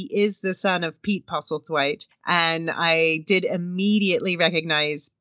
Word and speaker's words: Postlethwaite: Down Deep Deputy Hank, a.k.a., and I'm going to see if I can get the name right this time, Billy Postlethwaite? – Postlethwaite. – Okay, Billy Postlethwaite.